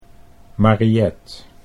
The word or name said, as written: Mariet